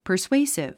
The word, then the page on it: persuasive: pərswéisiv; パァスウェイスィブ